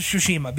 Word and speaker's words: Tsushima: No, it's Ghost of Tsushima because the T isn't pronounced. And I said it to him before, and he goes, "Oh, well, no, there's a T there."